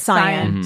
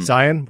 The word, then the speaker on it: Cyan: I think it's Cyan. – Cyan.